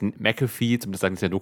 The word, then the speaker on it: McAfee: Soweit ich weiß, spricht man ihn McAfee aus und nicht McAfee, aber viele kennen ihn halt von seinem so, ja.